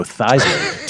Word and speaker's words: Theismann: You know, Joey Theismann once changed his name to Joe Theismann to rhyme with Heisman for his Heisman campaign, and no one knew him as Theismann again.